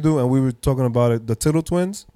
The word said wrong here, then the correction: Tuttle Twins: Oh yes, the Tuttle Twins. – How do you pronounce it? – The Tuttle Twins.